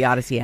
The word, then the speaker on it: Audacy: The Odyssey app. And by the way, that is spelled A-U-D-A-C-Y, like audio, audacious, and a journey, an odyssey, but spelled with the A-U-D-A-C-Y, Odyssey.